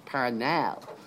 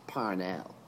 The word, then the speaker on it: Parnell: I mean, we call him Parnell now because it's been pointed out to us in the 20th century that the family hated the pronunciation Parnell. But to the Irish people, he was Parnell.